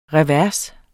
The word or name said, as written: revers